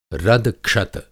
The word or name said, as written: रदक्षत